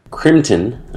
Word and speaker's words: Crimtan: Uh, uh, oh, sorry, uh, Crimtan. I think is how you pronounce it. Crimtan.